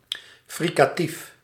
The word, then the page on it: fricatief: /ˌfrɪkaˈtif/